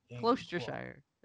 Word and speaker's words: Gloucestershire: Gloucestershire. It's like Worcestershire sauce.